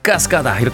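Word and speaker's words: Cascada: 독일의 유로댄스 그룹입니다. 가스가다 이렇게 발음해야 돼요.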